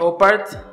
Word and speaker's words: OOPArt: OPAR, así como se escribe O-O-P-A-R-T, significa Out of Place Artifact.